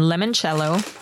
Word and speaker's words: limoncello: Also available in limoncello.